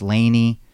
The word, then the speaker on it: LANY: The group is L-A-N-Y. So it's pronounced Laney, or it's L-A-N-Y. It's just an acronym to symbolize Los Angeles and New York.